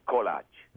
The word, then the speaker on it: kolach: The correct pronunciation is kolach.